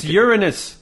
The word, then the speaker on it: Uranus: It's Uranus, okay? That's the British way of pronouncing it, and I like it.